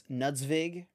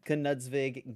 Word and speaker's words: Knudzvig: Sorry, I thought it was just Nudzvig at first, and then when I asked him how to pronounce his name, he was like, "Yeah, it's weird. You do pronounce the K."